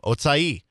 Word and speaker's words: Ochai: Ochai Agbaji said that's how you pronounce his name, Ochai.